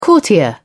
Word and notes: courtier: British English

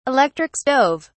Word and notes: electric stove: British English

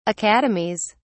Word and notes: academies: British English

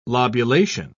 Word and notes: lobulation: British English

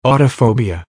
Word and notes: autophobia: American English

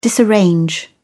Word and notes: disarrange: British English

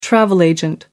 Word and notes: travel agent: American English

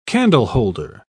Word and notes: candleholder: British English

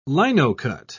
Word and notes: linocut: British English

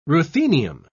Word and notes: ruthenium: British English

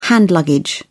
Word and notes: hand luggage: British English

